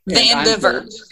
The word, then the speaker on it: Vandiver: Vandiver.